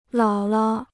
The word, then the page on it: 姥姥: lǎo lao